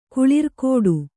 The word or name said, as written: ಕುಳಿರ್ಕೂಟ